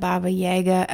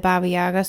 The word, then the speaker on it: Yaga: This is the Baba Yaga, or if you pronounce it Baba Yaga episode. I pronounce it Baba Yaga, so that's what we're gonna go with today.